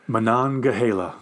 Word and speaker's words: Monongahela: I want to say McGonagall. That's not right. – Monongahela.